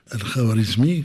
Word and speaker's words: Al-Khwarizmi: Je le prononce mal, prononcez-le pour moi, Roger Hachem. Oui, c'est-à-dire Al-Khwarizmi, comme on l'a dit, il a plusieurs contributions.